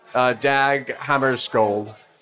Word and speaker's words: Dag Hammarskjöld: The unexplained death of the eminent United Nations Secretary General Dag Hammarskjöld.